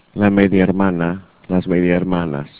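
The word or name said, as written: media hermana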